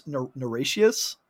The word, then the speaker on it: Niratias: I think it's Nirashius. – I was... – How it's pronounced. It's like N-I-R-A-T-I-A-S.